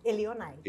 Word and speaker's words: Elionay: É, muita gente tem dificuldade em falar meu nome. Elionai. Elionai. – Ah. – Você pronunciou correto.